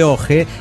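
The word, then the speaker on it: Ojje: Soy del pueblo Santiago de Oje, que se pronuncia Oje como ojo.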